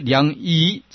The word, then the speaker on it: 两翼: 当知此之二法，如车之双轮，鸟之两翼之两翼吧，还是读作两翼？啊，两翼哈，啊，两翼，嗯，啊，两翼啊。